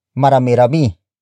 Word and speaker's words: marami-rami: Another way to express estimation when answering a question about ilan is by saying marami-rami. Marami-rami. Marami-rami. Marami-rami is spelled as M-A-R-A-M-I hyphen R-A-M-I.